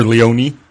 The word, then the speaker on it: Leone: Sunny Leone. – Okay. – I assume. Or Leone, depending on your pronunciation.